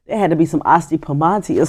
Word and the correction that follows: Asti Spumante: Asti Spumante. – And she didn't even say it right.